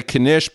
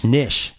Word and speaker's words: knish: Whereas if you hear a non-Jew talk about chutzpah, or they're trying to say knish, but they think the K is silent as it is in night. – Nish.